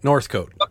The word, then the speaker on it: Northcote: Funny enough, while I was listening to that song and listening to his voice, I remembered how I knew, what is it, Northcote? How do you say it? Northcote? – Northcote. Like as if, just like a coat in the north, Northcote.